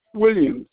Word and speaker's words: Williams: Maurice Williams. 1302 McCall Drive, Sherman, Texas. 75090. – Can you spell that first name for me, sir? – Maurice, Maurice. – Last name? – Williams, WILLIAMS.